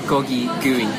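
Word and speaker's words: gogi gui: The name is pronounced Gogo Gogi Gooey. – Okay, so Gogi Gooey.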